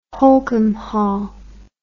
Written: holkham hall

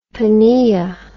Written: pnea

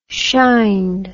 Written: shined

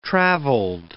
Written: traveled

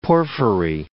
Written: porphyry

